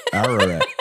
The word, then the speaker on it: Ararat: The Adidas Yeezy Foam RNNR Aratot. Ararat. How do you say it? A-R-A-R-A-T.